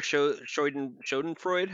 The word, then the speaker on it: Schadenfreude: For just like, there's no greater Schadenfreude. How do you pronounce that? – That's it.